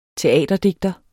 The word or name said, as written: teaterdigter